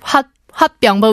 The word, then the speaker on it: 화병: Yes, there is a word 화병, but we pronounce that word 화병. – 화병 with yes. a little 받침 on the end.